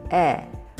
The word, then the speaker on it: 의: In this episode, we'll learn about the possessive particle that is spelled 의, but pronounced as 에, when used after a noun.